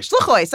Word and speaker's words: Shluchos: Shluchos, am I saying this right? – Nah, it's okay. – Shluchos, that's how they say it.